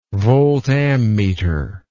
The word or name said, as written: voltammeter